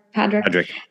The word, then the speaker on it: Padraic: Is that how you say his name? Padraic? – Padraic, yeah.